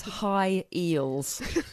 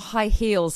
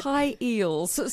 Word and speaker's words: high eels: Meanwhile, I've got a little note here about our next story, and it says high eels, and it's not high heels, and I'm saying it wrong. – No, it's high eels.